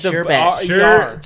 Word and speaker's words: sherbet: Yeah, it's sherbet. – Sherbet is a common misspelling of... Sherbet. – Yeah, I told you. That's what I said. There's no second R. – Resulted from a common mispronunciation.